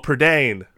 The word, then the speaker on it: Prydain: Chronicles, I'm probably not going to pronounce this right, Chronicles of Prydain. – Oh, Prydain. – Prydain. – Prydain. Yeah, yeah.